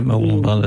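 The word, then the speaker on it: Mooloolaba: And we've got a show up in Mooloolamba, I hope I pronounced that correctly. – Yeah, Mooloolamba, that's right.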